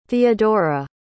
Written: theodora

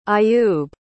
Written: ayub

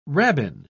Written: rabbin

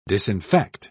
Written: disinfect

